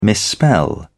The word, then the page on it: misspell: British English